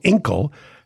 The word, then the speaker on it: Inkl: Australian startup Inkle, and I hope I'm pronouncing that right, INKL, Inkle, has partnered with 100 news sources like The Economist, The Atlantic, and Bloomberg.